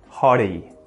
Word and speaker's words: hottie: If you want to say it like an Aussie, use that T flap, hottie, hottie. We often use this for hot water bottle, right?